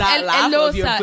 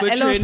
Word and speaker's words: Elohosa: I feel like you just butchered it. No, it's Elohosa. Elohosa, my name.